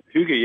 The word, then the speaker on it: hygge: It's hygge, yeah.